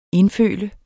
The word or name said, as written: indfølt